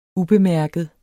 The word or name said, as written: ubemærket